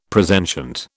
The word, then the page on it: presentient: British English